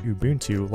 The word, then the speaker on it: Ubuntu: It's not Ubuntu like a sandwich, but Ubuntu like the Zulu word for kindness.